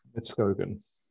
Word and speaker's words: Midtskogen: And how do you say his surname? Midtskogen and you said it right first time as well.